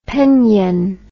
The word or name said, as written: penyen